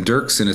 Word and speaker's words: Doerksen: So, well, yeah, so Brian is with an I, not Y, so B-R-I-A-N, and then Dirksen is spelled D-O. E-R-K-S-E-N. So the O is silent, but it's definitely there in the spelling.